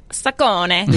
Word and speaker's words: Saccone: Wait, I'm bothered by it's Saccone and not Saccone. Because you know that one of my hidden skills... – The Americanization, I think, is Saccone. – You know one of my hidden skills is to correctly pronounce last names upon seeing them? And I would definitely like lean strongly toward Saccone.